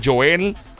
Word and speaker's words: Joel: Búscame bajo mi nombre, Joel el Intruder. Se escribe e J-O-E-L, Joel el intruder.